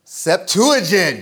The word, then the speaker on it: Septuagint: Starts with Sept, rhymes with Uigent. Septuagint, great job. We call that the Septuagint.